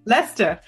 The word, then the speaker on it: Leicester: Okay, I can't say, I say Leicester. How do you say it? Leicester? – Leicester. – Yeah, Leicester.